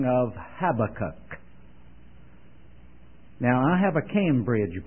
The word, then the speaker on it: Habakkuk: Now, if I do what I said some other people do and slip now and then and pronounce it Habakkuk, you'll understand. But it has been impressed upon me that the emphasis is on the last syllable, Habakkuk.